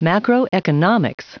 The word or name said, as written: macroeconomics